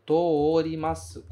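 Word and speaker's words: torimasu (通ります): Só que torimasu é uma exceção. Você prolonga o O do TO usando outro O. Você escreve TO desta forma. Ó, TO com outro O. E não TOU. Torimasu. Não é assim o verbo torimasu. Se pronunciaria da mesma forma. Mas não é assim que a gente fala. É sempre to-o nesse caso, certo?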